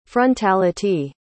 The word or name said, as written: frontality